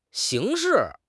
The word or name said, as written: 刑事